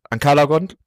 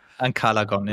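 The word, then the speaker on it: Ancalagon: Das sind der schon angesprochene Glaurung und dann später noch Ankalagon, spricht man es, glaube ich, aus, ne? – Ankalagon, ja. – Ja, genau.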